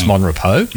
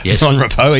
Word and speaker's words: Monrepos: It's Monrepos. Monrepos, even though it's spelled, as you said, R-E-P-O-S.